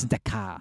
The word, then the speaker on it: Dakar: It's Dakar.